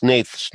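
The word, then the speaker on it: Hnath: That's how it's pronounced, right? – Yes, I believe so. – Okay. – That's what I've heard, yeah. – For those who don't know how it's spelled, it's H, N-A-T-H.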